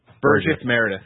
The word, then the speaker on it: Burgess Meredith: Burgess Meredith.